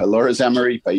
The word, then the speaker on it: Alora Zamaripa: A third singles match that helped you clinch the championship was from Alora Zamaripa. Did I get her name right? – Yes, absolutely. – Yeah. – Alora Zamaripa, yeah.